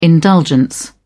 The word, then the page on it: indulgence: British English